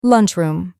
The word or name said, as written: lunchroom